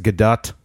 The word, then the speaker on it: Gadot: It is Gadot.